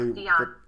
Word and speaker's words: Dionne: Yes, Dionne.